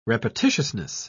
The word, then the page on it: repetitiousness: British English